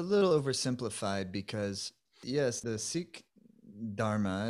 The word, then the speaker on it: Sikh: You actually said it right the first time. I mean, I usually say Sikh too, but the proper pronunciation is Sikh.